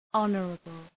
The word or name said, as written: honorable